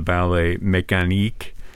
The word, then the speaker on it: ballet mécanique: Do you know, well, I mean, his most famous work is the mechanical ballet or the ballet mécanique. Is that the correct pronunciation? – Yes, absolutely.